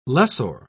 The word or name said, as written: lessor